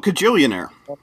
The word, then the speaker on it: Kajillionaire: Kilijilumernary. – Oh, Kajillionaire.